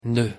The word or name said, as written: noeud